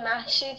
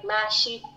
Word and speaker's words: Mahshid: But whenever I tried to pronounce my name, Mahshid, he repeated Ashley. Then he couldn't hear the rest. I kept saying like Mahshid and him with another white name.